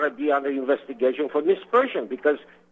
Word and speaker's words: misprision: Mike Rivera calls it misprision. Actually, it's pronounced misprision.